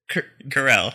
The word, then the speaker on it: Carell: Probably. Steven Carrera... – Carell. – Carell, yeah, I was saying it right.